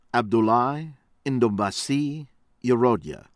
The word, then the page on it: Abdoulaye Ndombasi Yerodia: American English